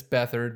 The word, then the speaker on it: Beathard: I mean, we saw CJ Beathard come in, and it's Beathard, but it's spelled Beathard. Beat CJ Beathard come in and not play really much better.